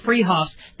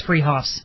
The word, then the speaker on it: Fruhoffs: It's spelled Fruhoffs, pronounced Freehoffs. They're at 33rd and Arapahoe in Boulder. That's Freehoffs.